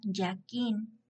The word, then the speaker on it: Jachín: Los números 1 y 2 son las dos columnas del Templo de Salomón, conocidas como Jachín, se pronuncia Yaquín, y Boaz.